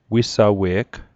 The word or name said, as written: wisawe'g